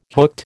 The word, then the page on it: hooked: American English